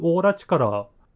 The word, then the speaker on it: オーラ力: 巨大な獣と書いて巨獣だったと思うんですけど、を利用して、あとはそのバイソンウェルに存在するオーラ力、これ漢字の力、力と書いてオーラ力、オーラカタカナに、漢字の力と書いてオーラ力と読むんですけど、オーラパワーじゃないですよね。